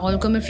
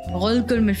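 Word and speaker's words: rødgrød med fløde: entonces la O, esa O en danés se pronuncia U. Oh, ok. Y la D es L y la E al final es como... – Entonces, y la R se pronuncia como en francés gol.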